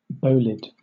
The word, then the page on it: bolide: British English